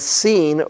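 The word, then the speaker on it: Sin (שׂ): And when the dot's on the right side, then we pronounce it as Shin. And if it's on the left side, we pronounce it as Sin or Sin.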